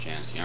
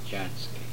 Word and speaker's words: Jansky: I might comment that over here in the United States, the name is always Jansky. Actually, in Europe, where there is no hard J, the name is pronounced Jansky, but it's always called Jansky here.